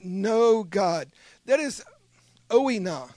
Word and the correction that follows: oida: that's what I want to say. Let me look at it again, oida. It's spelled like this, O-I-D-A. Do your own word search if you want to be like the Bereans and double check me here. OIDA is a Greek word.